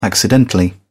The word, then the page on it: accidentally: British English